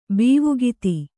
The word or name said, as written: ಭಾವುಕತೆ